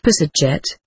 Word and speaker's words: psdjt: The original Egyptian word is psdjt pronounced psadjet, and it also translates by the 9.